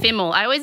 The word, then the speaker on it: Fimmel: How do you say? – Fimmel. – If you're trying to be fancy, you say Fimmel. – Fimmel. – But it's Travis Fimmel.